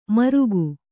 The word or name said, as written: ಮರುಗು